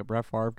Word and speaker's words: Favre: Why is his name pronounced like that? – Brett Favre? – Yeah. – I'm fucking up. – The R comes after the V. I'm pissed off about Brett Favre? – That's what it's spelled like.